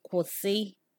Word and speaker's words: kwosi: Next, there is KW, which is pronounced we. An example of a word with we is kwosi, which means stop in English.